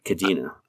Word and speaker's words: Kadena: Did I say that right? Kadena. It is.